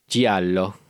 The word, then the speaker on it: Giallo: where I compared this to Giallo, is how you pronounce it.